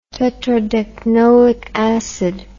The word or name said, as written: tetradecanoic acid